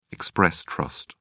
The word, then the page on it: express trust: British English